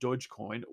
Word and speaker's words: Dogecoin: The price surge in Dogecoin, pronounced Dogecoin with a soft G sound, also coincides with rumors that Twitter is prototyping its own crypto wallet.